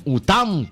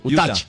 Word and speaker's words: Utah: Estoy en UTAM. ¿Qué es eso? UTAH. ¿Cómo se dice? – Utah.